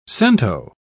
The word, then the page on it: cento: British English